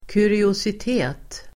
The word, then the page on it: kuriositet: [kuriosit'e:t]